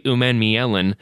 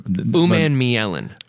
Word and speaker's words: Umanmielen: And say it one more time, because I have to learn it. – Umanmielen. – Umanmielen. – U-M-A-N-M-I-E-L-E-N. – Prinsley Umanmielen.